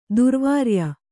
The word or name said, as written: ದುರ್ವೀರ್ಯ